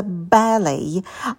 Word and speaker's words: ballet: Следующее слово – это балет, произносится ballet. Да, написано оно как балет, но читается иначе.